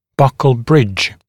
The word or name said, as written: buccal bridge